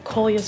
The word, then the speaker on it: Kolya: She says Kolya, am I pronouncing that right, Kolya? – Yes, Kolya.